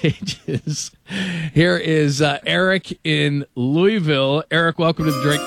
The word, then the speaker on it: Louisville: Yeah, the S is silent. It's Louisville.